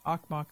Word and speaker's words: Okmok: If you look up KUCB, Okmok, that's spelled O-K-M-O-K, volcano.